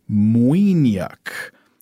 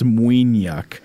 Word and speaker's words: maoithneach: The likelihood is that Walpole misheard a genuine Irish word, perhaps," and here I'm going to do my best with an Irish word here, "muiniach." Which is spelled M-A-O-I-T-H-N-E-A-C-H. Ireland, get it together. Come on. That's okay. I think it's muiniach, and it means sentimental.